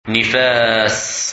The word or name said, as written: Nifas